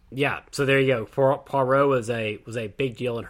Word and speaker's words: Poirot: I think she wrote, like, 50 Poirot novels, or Poirot, or I keep saying his name wrong. I'm so sorry. – Poirot.